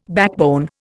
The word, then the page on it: backbone: Indian